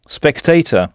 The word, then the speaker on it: spectator: Notez bien la prononciation. Spectator.